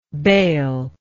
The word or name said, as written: Beyle